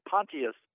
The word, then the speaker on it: Pontius: The T is not silent. It should be Pontius Pilate. It's not like poncho. – So it's not like partial. You know, often a T-I forms an S-H. But that's not right there? – There's no C-H sound in Pontius in the Latin.